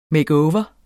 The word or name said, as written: over